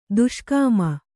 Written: ದುಷ್ಕಾಮಿ

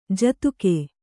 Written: ಜೊತ್ತಗೆ

